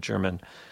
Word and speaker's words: Jermyn: After that, we're going to move on to the duo of piano player Luke Marantz and guitarist Simon German, that's spelled J-E-R-M-Y-N, German.